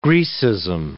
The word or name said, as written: Grecism